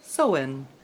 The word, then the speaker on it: Samhain: They held a festival, pronounced Samhain, spelled S-A-M-H-A-I-N, from sunset on October 31st to sundown the following day.